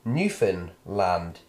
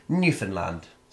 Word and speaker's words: Newfoundland: Newfoundland is not enunciated as if it were three distinct words, as in Newfoundland, nor is it pronounced, pr- oh for God's sake, pronounced as if it were somehow a Scandinavian colony, as in Newfoundland. Instead, it is Newfoundland. The key is to say it very fast Newfoundland.